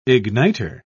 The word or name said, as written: igniter